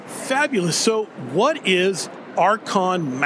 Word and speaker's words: Arkon: Did I say that right, Archon? – You said it beautifully.